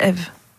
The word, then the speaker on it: EV: Ça s'écrit EV mais c'est Eve.